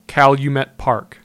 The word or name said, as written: Calumet Park